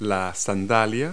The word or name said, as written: sandal